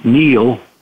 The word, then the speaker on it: Neal: It's my name, Neal, but it's spelled N-E-A-L, Grace.